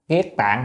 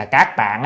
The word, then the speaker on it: kiết tạng: À, kiết tạng hay là các tạng á, có người ta tra từ điển cứ ra các tạng nhưng mà trong nhà Phật chúng ta hay đọc là kiết tạng.